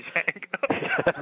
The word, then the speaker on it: Django: Or the D is silent, like in Django.